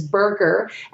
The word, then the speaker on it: BurGR: One in particular that I really liked is Burger, and that's spelled B-U-R-G-R, the G-R for Gordon Ramsay. So it's Burr and then G-R.